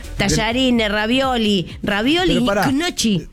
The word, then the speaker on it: gnocchi: Que la pronunciación de gnocchi en italiano es gnocchi.